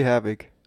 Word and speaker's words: Herbig: Tommy Schmidt, Anke Engelke, Bulli Herbiger oder irgendein Fußballspieler? – Toni Kroos, irgendein Fußballspieler. – Toni Kroos. – Aber auch Bulli Herbig. – Okay. Bulli Herbig, sorry, ich habe den Namen falsch gesagt.